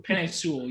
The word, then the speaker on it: Penei Sewell: Draft Penei. Penei Sewell. Yeah, there we go.